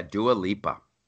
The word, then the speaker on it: Dua Lipa: And first of all, you have to tell me, is her name Dua Lipa? Is that the correct way to pronounce it? – Oh, my gosh. Yeah, Dua Lipa.